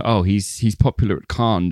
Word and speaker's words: Cannes: And then, you know, a little bit of a hand clap at Cannes Festival, he's going to lend some credibility. – It's pronounced Cannes, actually.